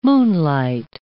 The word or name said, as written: moonlight